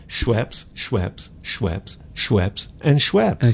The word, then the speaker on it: Schweppes: Schweppes. – Schweppes. – S-C-H. Schweppes. Rhymes with Peps.